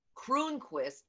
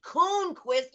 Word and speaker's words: Croonquist: And they'll come up to you and, you know, and having the name Croonquist, you know what it's like. You keep saying my name wrong too. Is no R in Sunda. It's Sunda Croonquist, but people forget the R and say Coonquist.